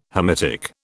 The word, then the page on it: Hamitic: British English